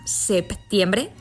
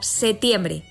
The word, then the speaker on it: septiembre: El Diccionario de la Lengua Española recoge tanto la forma septiembre con P antes de la T como septiembre sin ella. Esta última es una variante válida que se usa desde antiguo. Surge por la relajación en la pronunciación de la P cuando forma parte del grupo PT situado en interior de palabra. Su pérdida sucede a menudo en el habla espontánea,